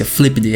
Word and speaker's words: Flipd: Assim mesmo que pronuncia Flipd, é Flipd, F-L-I-P-D,